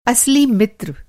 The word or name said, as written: असली